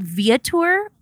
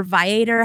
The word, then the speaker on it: Viator: A great site for that, it's called Viator or Viator, however you choose to pronounce it.